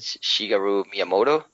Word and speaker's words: Shigeru Miyamoto: Is it Shigeru Miyamoto? – Yeah, that's right, yeah.